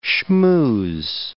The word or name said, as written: schmooze